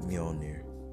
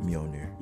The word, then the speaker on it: Mjolnir: Oh, Bobby Shmurda's hat came back like Mjolnir. That's that's the proper way to say it, Mjolnir.